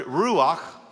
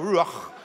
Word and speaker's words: ruach: In the Old Testament Hebrew, it's the word ruach, and it's pronounced like that, like you got a popcorn kernel stuck in the back of your throat, ruach. They got to say it like that, right?